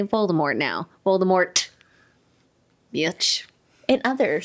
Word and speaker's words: Voldemort: And actually, the- that's not how you pronounce it. – Enlighten me. It's Voldemort. You don't pronounce the T.